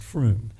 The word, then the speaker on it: Frome: Um, uh, some of you will know the town of Frome in Somerset in the UK, spelled F R O M E, if you want to look this up on the net, but I'm assured that it's pronounced Froome.